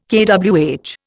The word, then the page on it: kWh: Indian